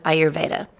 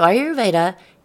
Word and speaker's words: Ayurveda: So I'm going to spell it for you because it looks completely different than what it sounds. Ayurveda is spelled A-Y-U-R-V-E-D-A.